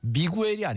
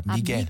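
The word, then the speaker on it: Miguel: 이게 가수가 미구엘이 아닙니다. 스페인어 발음으로는 미겔입니다. 미겔.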